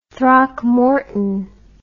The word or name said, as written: Throckmorton